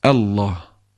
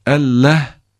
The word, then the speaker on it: Allah: Lam kalın okunacak. Lafzayı celal böyle okunur. Normalde öyle okunmaz. Başka kelimelerde Allah lafzında kalın okunur. Elleh denilmez. Allah. Ama Allah da değil. A yoktur bir kere Arapçada. Ayn var, hemze var. Ve hemze ile yazılıyor. Elif ile yazılıyor yani. Allah. E incedir. Yani bildiğimiz E. Lamsa kalın okunur. Allah. Elleh diye okunmaz. Allah diye de okunmaz.